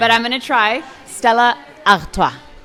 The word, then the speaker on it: Stella Artois: We would say Stella Artois in the Dutch-speaking part of Belgium, where Stella Artois is actually from, but people also pronounce it in French, Stella Artois.